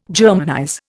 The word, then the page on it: Germanize: Indian